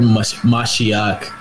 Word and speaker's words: Mashiach: So the word Mashiach... as it's pronounced in the Hebrew, which is in the English Messiah.